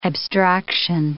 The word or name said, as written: abstraction